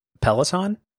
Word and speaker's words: Peloton: Is it Peloton? Is that not right? – I don't think so. But that's how do you pronounce it? – Peloton.